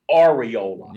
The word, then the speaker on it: Areola: Also, by the way, it's technically pronounced Areola.